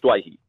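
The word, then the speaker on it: Doueihi: He informed me that week after having that first game, before he played his first NRL game, he said, no, I want it pronounced Dewey, because that's what my father has been, even though it's Dwayhi in the Lebanese community, my dad is Dewey, so I will respect him, and so I'm Adam Dewey.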